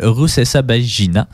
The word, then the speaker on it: Rusesabagina: Donc, c'est un film biographique sur Paul Rusesabagina. – Oui, tu l'as bien prononcé.